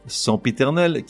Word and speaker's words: sempiternel: Sempiternel, qui se réfère à quelque chose de répété indéfiniment au point de fatiguer, s'écrit S-E-M-P-I, qu'on prononce logiquement sempi. Or, tu trouveras ça et là des gens pour te dire que, que nenni, sempiternel doit se prononcer saint Or, il n'en est rien. La prononciation correcte est bien sempiternel adéquate.